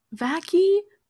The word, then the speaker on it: vaccy: Vacky? But it's spelled like V. That sounds like a vacuum. That just sounds like vacuum. – V-A-C-C-Y. Vacky. Get your vacky.